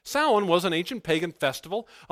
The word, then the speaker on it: Samhain: It looks like Samhain. You pronounce it Samhain,